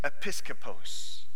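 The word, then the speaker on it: episkopos (ἐπίσκοπος): It's, I'm going to say it right. It's episkopos.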